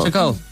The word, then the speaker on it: Shacole: I don't know how it's S-H-A-C-O-L-E. How would you pronounce it? Brilliant name. – Shacole? – Yeah, I love Shacole. It. I just love it. It's a great name. – Yeah. It's a bit like Shakira, but it's Shacole. – Shacole.